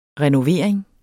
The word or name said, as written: renovering